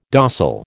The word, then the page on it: dossal: American English